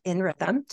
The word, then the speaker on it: nRhythm: She is an organizational design consultant at nRhythm. Did I pronounce that right? – Yep.